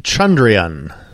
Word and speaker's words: Chandrayaan: It is pronounced Chandrayaan.